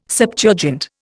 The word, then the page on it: Septuagint: Indian